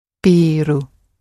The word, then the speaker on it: ビール: And with beer, again like coffee, it's a long sound at the beginning. コーヒー、ビール。 – ビール。